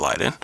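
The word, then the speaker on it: Liden: I think it's pronounced Leiden.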